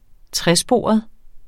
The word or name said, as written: tresporet